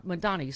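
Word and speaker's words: Mamdani: There's got to be, like, progressive affordability candidates like Mayor Mondami. – Yeah. – Mondani, sorry. – Mondani.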